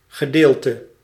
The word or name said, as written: part